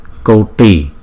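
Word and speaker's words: koṭi: Koti, Koti, Koti. Chữ T có dấu nặng ở dưới, chúng ta đọc là T, T. Uốn cong lưỡi lại và chúng ta bật ra, đọc thành chữ T, T, T.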